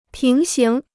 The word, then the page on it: 平行: píng xíng